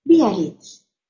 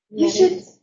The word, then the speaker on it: Biarritz: Biarritz. – Biarritz.